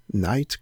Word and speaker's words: Knight: Knight comme le chevalier.